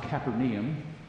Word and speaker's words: Capernaum: They went to Capernaum, or as I learned this summer in Israel, it's often pronounced Capernaum.